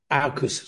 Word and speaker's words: AUKUS: Now, as you mentioned, there's a new formation, the acronym AUKUS, is that how it's pronounced? – AUKUS, yeah, AUKUS.